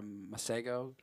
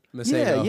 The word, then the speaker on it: Masego: I think it's Mas Ego or Masego. – Masego.